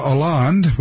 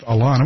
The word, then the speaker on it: Hollande: But the big news tonight is Francois Hollande, which looks like Holland, except it's pronounced Hollande.